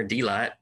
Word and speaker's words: Delight: He's native of Pike County, Arkansas, Delight, there, Delight. So the emphasis is on the D.